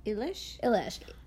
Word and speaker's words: Eilish: Listen, Billie Eilish, Eli... What's her... How do you pronounce her last name? Eilish.